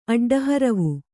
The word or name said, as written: ಅಡ್ಡಹರವು